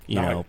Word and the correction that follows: Ngannou: I believe you pronounce it Ngannou.